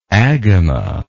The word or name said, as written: agama